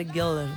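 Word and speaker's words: Göller: Me encanta tu pronunciación alemana, Goller. – Goller, porque tiene cremillas.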